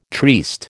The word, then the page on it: triste: American English